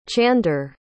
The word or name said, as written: chander